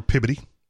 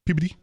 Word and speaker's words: Peabody: Or Peabody. Peabody. – Peabody. Peabody. – Yeah, Peabody. – Peabody.